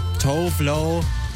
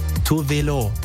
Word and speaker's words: Tove Lo: Das ist für viele Leute Tough Low, aber eigentlich spricht man sie Tuvelo.